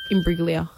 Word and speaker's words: Imbruglia: Imbruglia. Put it down. I'll give you that. – Soft G. – Soft G. – Torn. – Soft G. Soft G. – Imbruglia.